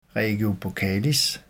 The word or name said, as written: regio buccalis